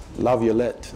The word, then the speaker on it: Laviolette: But in 1983, way ahead of his time, Paul Laviolette, how do you say his last name? – Laviolette, okay.